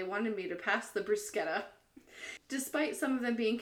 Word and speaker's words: bruschetta: I said, we'll start with the bruschetta, pronouncing it bruschetta, as it is pronounced in Italian.